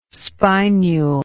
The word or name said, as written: spinule